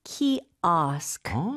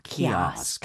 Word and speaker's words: kiosk: 이거는 발음이 좀 중요할 것 같은데요. – 맞아요. 영어로는 키오가 아니고 키오스크. 키오스크. – 오가 아에 가깝게 발음이 되네요.